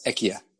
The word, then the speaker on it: IKEA: Thank you for IKEA, by the way. – It's actually pronounced IKEA.